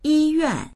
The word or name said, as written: yi1yuan4